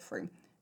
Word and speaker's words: Frome: And that is why it filled me with such joy when I read that in Frome, which is spelt Frome, and I recently learned that you pronounce it Frome, it's Frome,